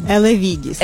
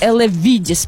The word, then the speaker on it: Елевідіс: Я завжди дуже боюся неправильно вимовити це препарат, але я думаю, що мені зараз допоможе. – Елевідіс. – Елевідіс, правильно. Для Данилка Вінтонєва.